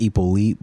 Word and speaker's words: Hyppolite: It's pronounced Hyppolite, but all right. We'll take it. – All right, word. – The H is silent.